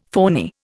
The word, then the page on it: phoney: Indian